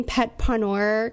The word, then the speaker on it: petpreneur: Am I pronouncing petpreneur correctly? Yes, absolutely. It's, okay. So it's a play on the word entrepreneur.